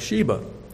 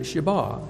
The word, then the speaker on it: Sheba: And, of course, I've always pronounced that word Sheba, which English speakers will tend to do, but I checked the Blue Letter Bible pronouncing guide, and he pronounces it Sheba.